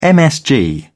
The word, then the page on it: msg: British English